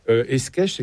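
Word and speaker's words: Escaich: Vous parlez du titulaire des orgues, un des organistes, les compositeurs les plus extraordinaires, il s'agit de Thierry Esquèche, c'est comme ça qu'on le prononce.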